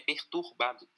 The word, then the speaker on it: perturbado: Eu descobri que tem um R na, na, na segunda sílaba esses dias, não sabia que tinha. – E aí Sério? a série me. – Uhum, é perturbado.